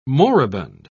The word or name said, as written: moribund